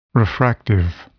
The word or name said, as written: refractive